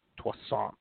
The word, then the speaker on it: Toussaint: Toussaint. He's a croissant.